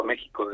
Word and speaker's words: México: La X, hay una cuestión ahí de... de pronunciación, sí. – Pero yo veo que aquí también se utiliza la J, pero sí. Vamos, lo correcto siempre ha sido México desde hace mucho tiempo, ¿no? – Vale, sí.